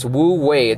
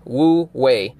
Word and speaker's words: Wu Wei: It's Wu Wei. It's spelled W-U space W-E-I. Wu Wei.